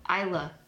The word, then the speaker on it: Isla: How do you say the name Isla? – Isla. – Really? – Yeah. – The S is silent? – Mm-hmm. – Why is it there? – Like island.